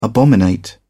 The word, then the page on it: abominate: British English